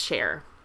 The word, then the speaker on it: Cher: She went by Cher, which is spelled S-H-E-R-E, but pronounced Cher.